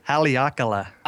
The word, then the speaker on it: Haleakala: He took it from the top of, now tell me, if I say this wrong, Haleakala? – Haleakala. – Haleakala. – Couldn't have guessed that.